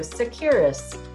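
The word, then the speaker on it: Tsakiris: My name is spelled T-S-A-K-I-R-I-S. I feel like I need to spell it because the T is silent. So if you just go Securus, you're in good shape.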